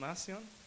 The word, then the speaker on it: Marcion: I'd pronounce it Marcion. Is it Marcion? Ah, excellent, excellent.